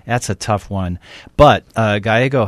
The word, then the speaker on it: Gallego: But now a state lawmaker, Assistant House Minority Leader Ruben Gallego, is that the right pronunciation? – I believe it's Gallego, so.